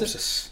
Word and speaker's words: Popsys: The next one I'm going to put out there is James Popsis. Is that how we pronounce Popsis. – It? Popsis. So P-O-P-S-Y-S.